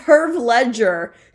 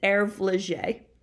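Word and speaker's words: Hervé Léger: No, it's Herb Ledger. – I'm pretty sure it's Herve Ledger, but it's French. You don't pronounce the H in French. It's Herve Ledger, and you don't pronounce the ER.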